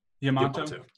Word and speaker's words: Yamato: Yamato. – I'll check out Yamato. – It's spelled like tomato except with a Y and an O, or Y and A at the beginning.